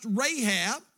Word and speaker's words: Rahab: That's in the Old Testament pronounced Rahab.